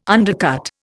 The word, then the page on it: undercut: Indian